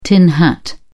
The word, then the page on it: tin hat: British English